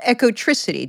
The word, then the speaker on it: Ecotricity: It's called Ecotricity. Did I pronounce that properly? – Yeah, Eco is fine. I say eco, but eco, tomato, tomato. We don't mind, do we? – Us Yanks with our flat vowels.